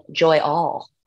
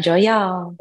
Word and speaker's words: Joy Y'all: Someone else just said Joy All. I was like, no, Joy Y'all.